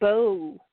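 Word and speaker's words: Beau: B-E-A-U-D-E. I think that's supposed to be pronounced Bow.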